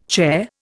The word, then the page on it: charr: Indian